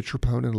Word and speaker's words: troponin: And it's called a troponin level, T-R-O-P-O-N-I-N. The English pronounce it troponin.